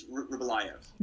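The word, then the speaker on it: Rybolovlev: Is that, am I saying it right? We call him RoboCop because I can't understand his name. But so yeah, I think it's Ryabalov.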